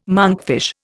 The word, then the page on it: monkfish: Indian